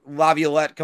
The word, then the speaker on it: Laviolette: I think this is where Peter Laviolette comes into play. Didn't mispronounce it.